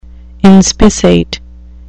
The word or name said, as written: inspissate